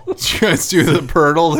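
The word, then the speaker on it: Poeltl: Players, yeah, because there's a guy named Jakob Purdle, but it's actually spelled like P-O-L-T-L or something like that.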